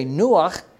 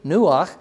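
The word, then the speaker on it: Nuach (נֹחַ): So Noah's name, which is how we say Nuach, that's how you say his name in Hebrew, Nuach, but Noah is how we say it in English.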